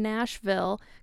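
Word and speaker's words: Gnashville: Yoni Wolf, I've always wondered about the song Nashville because it starts with a G. It's like the silent G, like gnashing and the city Nashville.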